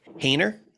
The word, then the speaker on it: Haener: Jake Haener. Did I say that right? – Yep.